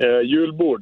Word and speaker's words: Julbord: Is it Julbrod? Julbrod. – Julbord, yeah.